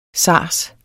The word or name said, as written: SARS